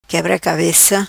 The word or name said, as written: Quebra Cabeca